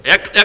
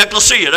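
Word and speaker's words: Ecclesia (ἐκκλησία): Ecclesia. – Ecclesia, there you go.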